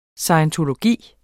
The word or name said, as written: SCIENTOLOGY